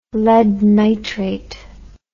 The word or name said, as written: lead nitrate